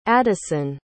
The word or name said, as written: adison